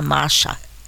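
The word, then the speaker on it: Masha (משה): Segundo a, o Bureau de Estatísticas, menos, eh, pelo menos 90 mulheres têm eh, o primeiro nome com a grafia eh, de Moshe, em hebraico, mas se pronuncia Masha, que quase soa como, eh, ou é um nome, muito comum em línguas eslavas, mas é escrito da mesma forma que Moshe.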